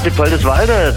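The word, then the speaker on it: Dippoldiswalde: Habe ich das überhaupt richtig ausgesprochen? – Ja, Diepoldiswalde, das liegt in der Nähe von Dresden.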